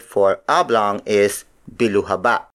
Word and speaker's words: biluhaba: The next shape is biluhaba. Biluhaba. Biluhaba. Biluhaba is spelled as B-I-L-U-H-A-B-A.